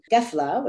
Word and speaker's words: Gefla: And I have this little recording, Gefla, which is the Oranian or Maskar pronunciation of Qafila or caravan.